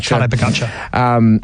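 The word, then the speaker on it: Pogačar: how do you pronounce his name? Taj Podokar? – Pogacar. Tadej Pogacar.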